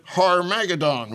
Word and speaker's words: Armageddon: If we will not devise some greater and more equitable system, Armageddon will be at our door. – Yes, Armageddon will be at our door, or as General MacArthur pronounced it, Har-Magedon will be at our door.